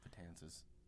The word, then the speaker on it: Betances: Yeah, Betances, I think is how you pronounce it. – Betances.